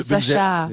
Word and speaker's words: Sacha: Ja, ist eine Variation. – Okay. – Kannte ich auch am Anfang nicht. Französisch, Sascha. – Sehr, ich bin sehr froh, dass du das ausgesprochen hast.